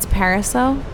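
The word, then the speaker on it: Pariseault: It's Pariseau. – Pariseau. – So you don't pronounce the L-T at the end. – I've definitely been saying Parasalt.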